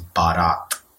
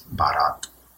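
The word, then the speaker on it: baraat: And the right pronunciation for that word is barat. So yeah, barat. That's barat.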